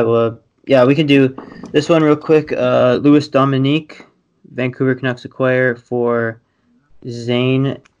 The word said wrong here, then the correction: Domingue: It's Louis Domingue and Zane McIntyre.